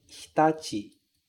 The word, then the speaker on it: Hitachi: Um cuidado que a gente tem que ter é de pronunciar bem o H, hi. Esse CHI no final ser pronunciado ti, hitachi. E veja que quando eu pronuncio hitachi, eu não falo hitachi, hitachi. Esse I aqui, ele quase some, né? Ele fica quase mudo. Eu falo assim, ó, hitachi, hitachi.